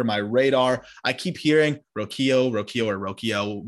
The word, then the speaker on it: Rocchio: And am I saying that right before you start correcting me? Rocchio? – Yes. Yeah. – Not Rocchio. Rocchio. – Rocchio. Yeah.